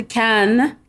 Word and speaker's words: Cannes: Is it Cannes or is it Cannes? How do I properly pronounce this film festival? – It is the Cannes Film Festival, and Caen, C-A-E-N, is a city that's in Normandy where it's cold and they make apples and cider.